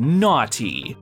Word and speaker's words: Gnawty: It's a little rat looking thing called Gnawty, that's spelled G N A W T Y. Like naughty, but gnaw, like you're gnawing on a piece of beef jerky, for instance.